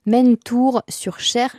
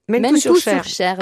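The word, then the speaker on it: Mennetou-sur-Cher: Zabou Bretman, vous quittez Paris donc pour le Loir-et-Cher, pour Mentour-sur-Cher, je le prononce bien? – Bon, vous avez rajouté un R, mais qui n'est pas nécessaire, parce qu'il y a Tour aussi à côté.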